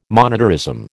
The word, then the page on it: monetarism: American English